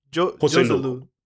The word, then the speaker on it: Joselu: So how do we say his name? – Joselu.